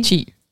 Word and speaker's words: qi: Want hoe spreek je het uit? Qi? Levensenergie? – Qi. – Qi. Oh, het is qi.